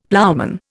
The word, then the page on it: ploughman: Indian